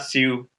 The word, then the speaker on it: Siu: Siu. Yeah, it's like kind of one and a half syllables.